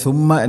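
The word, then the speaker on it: സുമ്മ (ثم): ലൈലതൻ സുമ്മ അതുപോലെ സുമ്മ എന്നതിൽ ഫാഇൻ്റെ ഉച്ചാരണം ഒരിക്കലും സീനായിട്ട് വരരുത്, അത് അർത്ഥം മാറും.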